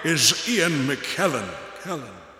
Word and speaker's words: McKellen: No, I said it rhymes with Helen. It's not Ian McKellen.